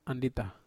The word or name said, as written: andita